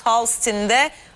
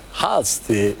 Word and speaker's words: Hull City: Sen İngilizceden telaffuz ediyorsun. – Efendim? – Çık. – Efendim? – Ders. Yok, sen telaffuzu İngilizce yapıyorsun, ben Türkçesini yapıyorum yani. – Evet, Hull City diyorsun değil mi sen? – Hull City. Asıl olan doğrusu Hull City'ymiş ya.